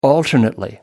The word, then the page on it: alternately: British English